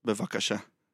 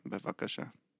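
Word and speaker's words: Bevakasha (בבקשה): Bevakasha. – Well, that Bevakasha. – Was, slow that Bevakasha. One down for me. – Bevakasha. – Bevakasha? – Yes. – Bevakasha? Bevakasha. – Exactly. Bevakasha.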